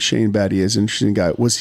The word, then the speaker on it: Battier: Shane Battier. – Good job pronouncing his name right.